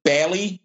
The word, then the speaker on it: Bale: Actually, most people pronounce it Bailey, but like the Irish. I mean, his name's not Irish, but like the Irish name Bailey.